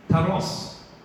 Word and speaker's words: kairos: He's using the word, try and say it properly, kairos, which is a measured, allocated epoch of time.